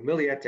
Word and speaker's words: Millete: Millete is how her name's pronounced.